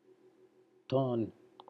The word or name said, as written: Ton